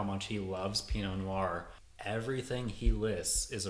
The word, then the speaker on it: Pinot: If people ask you, should they plant Pinot, break it down into syllables for them. P, no. Emphasis on the no.